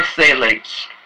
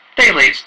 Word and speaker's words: phthalates: What are phthalates? First off, the P is silent, and so are penises.